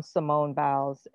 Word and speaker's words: Simone Biles: on Simone Biles, and did I say the name right? – Yeah, I believe so, yeah.